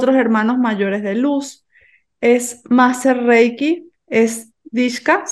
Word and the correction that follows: Diksha: ¿se pronuncia así? Diksha. Disha.